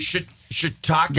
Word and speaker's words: shiitake: Shiitake. – Yeah, shiitake's right. – You said it right.